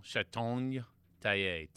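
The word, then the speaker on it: Chartogne-Taillet: My favorite champagne, I'm going to butcher the name, Chateau Tallier. – But for the readers at home, if you guys can pronounce it, C-H-A-R-T-O-G-N-E, like Gascogne. – Yeah. – Like Bourguignon. – Bourguignon. Chateau Tallier. T-A-I-L-L-E-T.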